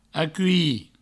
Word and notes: aculhir: acui

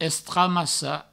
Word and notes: estramassar: Provençal; estramassa

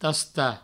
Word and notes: tastar: provençal; tasta

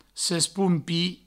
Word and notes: sespompir: sespoumpi

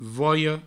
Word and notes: volha: voio

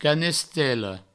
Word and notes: canestela: provençal; canestello

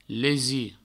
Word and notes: lesir: provençal; lesi

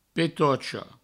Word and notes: petocha: Provençal; petocho

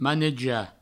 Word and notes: manejar: maneja

